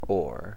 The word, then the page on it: ore: American English